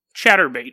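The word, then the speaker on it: Chaturbate: It's a headache-inducing website known as, even though it's pronouncing it's hard, Chatterbait. Chatterbait? Chatterbait. It's not like chattering your teeth. It's like chat.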